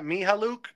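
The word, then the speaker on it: Mykhailiuk: I'm going to butcher his name, but I'm told it's pronounced like this. Mihaluk. Yeah, I think I said that right.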